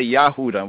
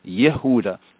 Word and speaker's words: Yehuda: In Yehuda. Some might say Yahuda. We're going to touch on what is the meaning of Yehuda. Why is Judah in Hebrew properly pronounce Yehuda and not Yahuda,